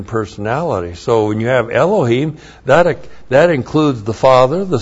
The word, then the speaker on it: Elohim: And then Elohim, Elohim, Aleph, E-L-O-H, long I-M, Elohim.